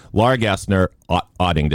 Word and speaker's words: Laura Gassner Otting: Laura Gassner, Otting, did I say that correctly? – You did.